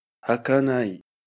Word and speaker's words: 儚い: 日语将这个蒙字用作形容词，一直短暂无常，又指虚幻而不可靠，如人发梦，念作 hakanai hakanai。